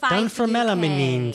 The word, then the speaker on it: Dunfermline: Dunfermline.